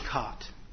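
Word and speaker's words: khat: Spell the thing K-W-A-T? – It's spelled Q-A-T-K-H-A-T. There are multiple spellings. – How would you pronounce it? – I pronounce it cot.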